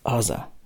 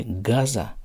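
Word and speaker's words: Аза (עזה): Что из сектора Азы, это именно так на иврите произносится, Аза, не Газа.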